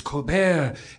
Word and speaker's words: Colbert: So I asked him, is it Colbert or Colbert? And he said, it's the French, it's Colbert.